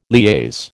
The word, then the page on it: liaise: American English